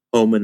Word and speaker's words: Hohmann: Homan is spelled H-O-H-M-A-N-N for your great audience out there who might want to keep up with my work.